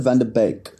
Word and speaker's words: van de Beek: Yes, apparently you pronounce it van de Beek.